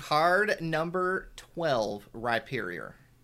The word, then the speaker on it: Rhyperior: It was Rhyperior. – Ah. Wow. – The word hype is in the name. You pronounce it, you say the rye.